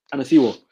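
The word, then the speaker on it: Onisiwo: Onisivo, I'll say. – Onisivo, I'll pronounce the W as a V because he's playing in Germany.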